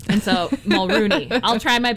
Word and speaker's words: Mulroney: And I learned its last name's pronounced Mulrooney, not Mulroney. – Okay, Mulrooney. – Canadians said that it's Mulrooney.